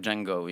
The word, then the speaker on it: Django: I was so like it's like Django, you know, the D is silent.